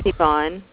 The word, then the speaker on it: Yvonne: My name's Yvonne. – Yvonne, and how do you spell that? – Y-V-O-N-N-E.